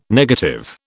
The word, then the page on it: negative: British English